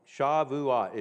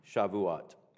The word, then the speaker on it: Shavuot: Shavuot is how it's pronounced. That's what the Jews call it, Shavuot.